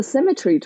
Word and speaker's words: Symetra: If they don't know, then, you know, it's like, Liz and I are both played on tour, the Symetra tours, like how you pronounce it. The Symmetry Tour.